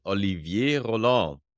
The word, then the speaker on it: Olivier Roland: Today is August the 26th in 2023, and my guest is Olivier Roland.